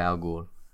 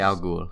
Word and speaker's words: al Ghul: Talia al Ghul. – Yeah. I'm not going to try and pronounce that. – Just say al Ghul.